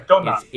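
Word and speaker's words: Dontnod: Is it Donod? I think it's Donod. – Yeah, Donod.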